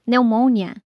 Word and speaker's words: pneumonia: Então já sabe, em palavras como pneumonia, em inglês, pneumonia, psicologia, em inglês, psychology, a letra P não se pronuncia.